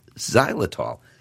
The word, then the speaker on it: xylitol: It begins with the letter X, but it's pronounced xylitol.